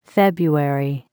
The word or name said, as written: February